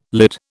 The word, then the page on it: lid: British English